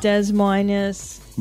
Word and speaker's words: Des Moines: That's not how you pronounce any of that. – Omaha. – It's Des Moines.